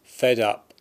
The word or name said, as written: Fed Up